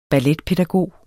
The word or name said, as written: balletpædagog